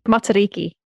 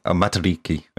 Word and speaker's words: Matariki: So how do you say then, Kiwi South African person? – Matariki. – Matariki, okay. – Matariki. – Matariki. – You got to roll the R's.